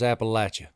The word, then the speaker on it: Appalachia: And right there on a broadcast, live on the football game, I said, sir, that's Appalachia. – Appalachia, boys. – It's commonly mispronounced.